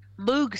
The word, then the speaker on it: Moog: You're right, because, I mean, it was, you know, like Moog says, some people pronounce it Moog, it's probably Moog, M-O-O-G, that he based his figuring out the synthesizer and to invent that on the theremin.